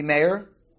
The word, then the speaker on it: Meir: And obviously, Rebbe Meir, right? Did I say it right? Yeah.